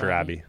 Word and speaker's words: Robbie: Robbie or Roby? I forgot. – Pretty sure it's Robbie.